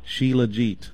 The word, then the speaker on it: Shilajit: I'll probably butcher it, but I think it's spelled S-H-I-L-A-J, as in Jason, I-T. Shilajit.